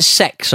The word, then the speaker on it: CeX: And, they pronounce it sex over here, but that's not how it's spelled. CEX, you know, the computer exchange.